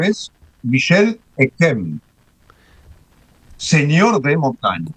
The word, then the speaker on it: Montaigne: Pero en el francés del siglo XVI, la I, que genera ese sonido en francés de E, una E abierta, cumplía otra función fonética y se pronunciaba montaña.